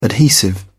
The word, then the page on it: adhesive: British English